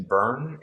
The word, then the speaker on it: Byrne: And so it's Jason Byrne, and the last name is spelled B-Y-R-N-E.